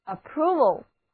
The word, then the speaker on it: approval: 字母 o 读作长的 u， approval 的意思就是赞成、同意、批准。